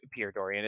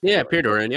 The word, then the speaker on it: Pierre Dorion: So to even have a mess up like that, did I get the name right? It's not Pierre Dorion. It is Pierre Dorion. – Pierre Dorion. Yep.